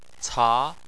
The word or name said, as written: ca2